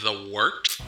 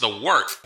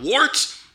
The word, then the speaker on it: The Wort: It's pronounced The Wort. – What is it? The Wort? Yeah, okay, I'll do that again. This is The Wort on Govs Radio. – It is The Wort, not wart, not fort, not short. The Wort.